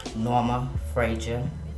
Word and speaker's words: Norma Frazier: Norma Frazier.